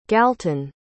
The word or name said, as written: galton